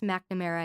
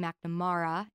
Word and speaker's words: McNamara: So Mara is also the last four letters of my last name, which is pronounced McNamara in the U.S., but it's actually McNamara in Ireland.